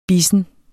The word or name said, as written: bissen